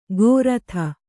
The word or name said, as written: ಗೋರಥ